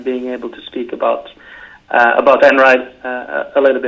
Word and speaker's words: Einride: I always thought it was Einride. I thought it was E-I-N and I thought it was German, but it's actually pronounced Einride.